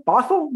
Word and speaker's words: Bothell: Bothell, did I say that right? – That's the right word.